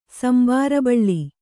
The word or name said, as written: ಸಂಬಾರಬಳ್ಳಿ